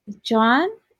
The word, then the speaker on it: jawn: John is spelled JAWN.